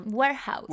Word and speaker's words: Warehouse: Warehouse.